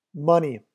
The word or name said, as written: money